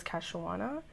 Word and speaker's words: Kei-shawonna: Okay, so basically, my name I always told people was Kei-shawonna, but when you're in ele- elementary school, for some reason kids have a very hard time pronouncing pronouncing that.